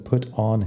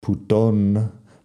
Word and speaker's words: put on: Fíjate que put on no suena al español, eh, al español putón, pero mucha gente lo quiere eh, pronunciar así si estás pronunciando todo como si fuera el español, pero no es nada parecido en inglés.